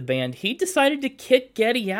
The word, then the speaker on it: Geddy: His real name is Gary Lee, but his like great-grandmother or something pronounced Gary as Geddy.